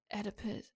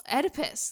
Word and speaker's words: Oedipus: I had to stop a night to think about it, and I was like, how did you pronounce Oedipus earlier? – Fuck. Like edible. – Exactly. That's why I was like, Oedipus, edible. Oedipus.